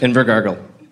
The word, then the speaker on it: Invercargill: Hatch settled in Invercargill? – Yep. – Invercargill, New Zealand? – Invercargill. Yeah. Don't laugh, guys. That's how it's pronounced.